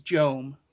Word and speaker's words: Joem: Yeah, so the reference was the mayor's first name is Joem, which is J-O-E-M, and his name is Antana.